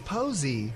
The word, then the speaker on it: Povse: P-O-V-S-E. – It's Posey. – It's Max Posey. – But it, the okay. – V is silent.